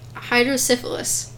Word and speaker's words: hydrocephalus: According to one story, they were originally children with hydrocephalus. I said it right. – You got it.